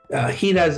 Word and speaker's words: jiras: Giras is spelled J-I-R-A-S, giras.